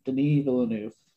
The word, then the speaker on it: Denis Villeneuve: You know, I'll say it right this time, Denis Villeneuve.